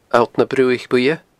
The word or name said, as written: alltnabruaichbuidhe